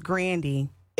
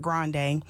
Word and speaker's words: Grande: And for those of you that don't know, her last name is actually pronounced Grandy. It's Grandy, not Grande.